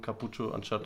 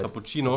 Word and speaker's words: Cappuccino: Ey, ich dachte immer, das wäre ein Gag, weil irgendwelche Leute das äh, weil dumm sind und dann falsch aussprechen. Cappuccio anstatt ja, Cappuccino.